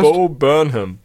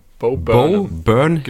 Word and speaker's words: Bo Burnham: Wie heißt der? Bob Burnhurst? – Bo Burnham. Burnham. – Bo Burnham. – Geschrieben Burnham? Burnham, aber Burnham ausgesprochen.